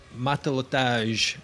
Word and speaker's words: Matelotage: So, while Matelotage are a new band... – We have confirmed that's how that's pronounced.